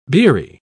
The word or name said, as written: beery